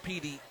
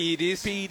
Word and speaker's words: PEDs: And I'm talking about pre and post PD. Am I saying it right? – PEDs.